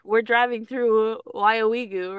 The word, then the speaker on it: Weyauwega: It's pronounced Weyauwega.